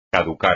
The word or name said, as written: caducar